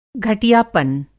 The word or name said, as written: घटियापन